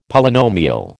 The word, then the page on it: Polynomial: American English